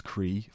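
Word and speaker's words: CRAI: They came together under the name Combined Residents Against Incineration, which they pronounce Cree for short.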